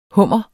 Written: hummer

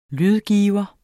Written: lydgiver

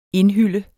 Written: INDHYLLER

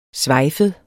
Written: svejfet

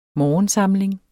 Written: morgensamling

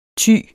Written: tys

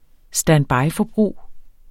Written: standbyforbrug

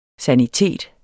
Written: sanitet

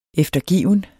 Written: eftergiven

